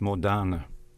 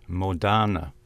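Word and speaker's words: Magány: It's pronounced Modana. – Say that again? – Modana. G in Hungarian in that context is pronounced D.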